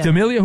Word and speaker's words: D'Amelio: Dixie D'Amelio. Is that, I'm saying that right? Yeah, but it's 25. Is that an actress? – D'Amelio.